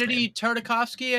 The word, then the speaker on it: Genndy Tartakovsky: How do you say his name? Is it Genndy Tartakovsky? I never know how to pronounce it. – Genndy Tartakovsky, I think.